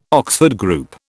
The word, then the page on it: Oxford Group: British English